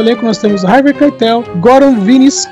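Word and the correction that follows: Višnjić: Goran Visnik.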